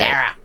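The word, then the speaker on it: Darra: Dara. Am I saying it right? – Dara. – I got to get a bit more nasal there, don't I? – Yeah, that's you really got to... – Dara.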